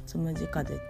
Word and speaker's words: 旋風: まあ、余談ですけどこの「つむじ風」って漢字で書くと「旋風」って読むんですね知らなかったはいで、